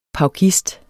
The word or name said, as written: paukist